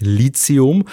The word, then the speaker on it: Lithium: Eigentlich heißt es Lithium und nicht Lithium.